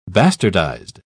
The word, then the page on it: bastardized: British English